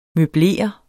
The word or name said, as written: møblere